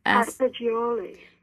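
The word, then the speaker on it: Assagioli: Roberto Astagioli.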